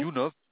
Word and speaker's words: YouNov: YouNov.